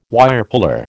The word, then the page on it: Wirepuller: American English